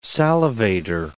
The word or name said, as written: salivator